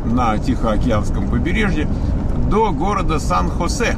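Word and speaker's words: San Jose: Его по-разному произносят. Это тихий ужас. Там одни говорят Сан-Хосе, другие говорят Сан-Хосе, третий говорит Сан-Хосей. Вот. Правильно, если вы будете смотреть там, чего пишут люди, то там типа Сан-Хосей.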